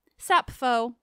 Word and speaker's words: Sappho: Also, I'm just going to be a nerd and share that in modern Greek, it's pronounced Sappho, which I love.